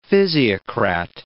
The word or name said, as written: physiocrat